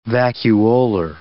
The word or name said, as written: vacuolar